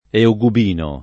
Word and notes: eugubino: italiana

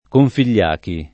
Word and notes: Configliachi: Italian

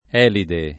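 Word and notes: Elide: italiano

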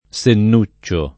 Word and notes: Sennuccio: lingua italiana

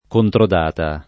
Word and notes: controdata: lingua italiana

